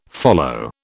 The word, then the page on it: follow: British English